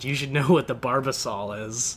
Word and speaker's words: Barbasol: How did you pronounce Barbasol? Barbosol? – Barbosol? – Barbasol is like it's one like of the biggest shaving cream. – It's like barbacoa at Chipotle. – It's pronounced Barbasol.